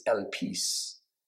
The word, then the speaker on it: elpis: The Greek word is elpis. Elpis. And I believe it's spelled in English, or, you know, our letters, E-L-P-I-S.